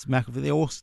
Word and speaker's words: McAfee: What about McAfee's? – McAfee still around. – Excuse the mispronunciation. – Yeah, it's McAfee.